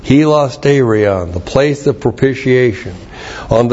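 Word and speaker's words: Hilasterion (ἱλαστήριον): I think I did a good job of messing that up. Ta-da! Hilasterion, H-I-L-A-S-T, long E-R-I-O-N, Hilasterion.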